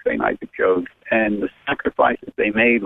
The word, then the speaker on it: Jogues: do you say it Jogues? Is that how you pronounce it? Jogues, Father Jogues.